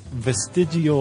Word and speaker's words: vestigial: Vestigial organs. – Nope. – You have to say it like you're all like you're eating a meatball. Vestigial.